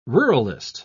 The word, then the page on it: ruralist: British English